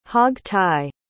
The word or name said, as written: hog-tie